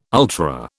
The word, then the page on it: ultra: British English